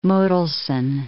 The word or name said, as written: Mottelson